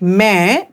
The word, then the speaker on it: मैं: Both of them are pronounced differently. Just pay attention to their pronunciation. While pronouncing main, I'll repeat main, that means I, mouth is widely opened. While pronouncing may, I'll repeat may, that means in, mouth is not opened so much.